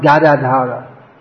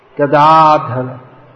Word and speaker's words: Gadadhara: So, at least we could say his name properly if we want to worship him. Not Gadadhara. Gadadhara.